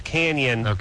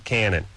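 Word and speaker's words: Cañon: That's what I was just It is pronounced Canyon, but they call it Cannon.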